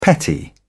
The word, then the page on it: petty: British English